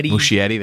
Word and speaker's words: Muschietti: Andy Muscanetti. Is that how you say his name? – Muschietti, there you go.